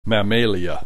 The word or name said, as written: Mammalia